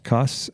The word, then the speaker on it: Cuss: And I'm just, it's by Steve, I think it's pronounced Cuss, C-U-S-S.